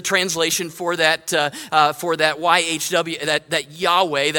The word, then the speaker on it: Yahweh: The Hebrew four-letter name for God, YHWH, how we would pronounce Yahweh, well, they wouldn't have said that.